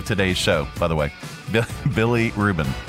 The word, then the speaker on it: bilirubin: Bilirubin. – Bilirubin? It's B-I-L-I-R-U-B-I-N, but it sounds like a sandwich.